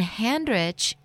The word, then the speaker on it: Handrich: You can always text in a question to my Facebook page, which is Coach Debbie, and it's spelled D-E-B-B-Y. You can go to my personal Facebook page, which is Debbie Handrich. It's D-E-B-B-Y. And Handrich is just like it sounds, H-A-N-D-R-I-C-H.